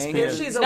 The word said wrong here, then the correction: Espen: No, it's Espen. It's pronounced Espen.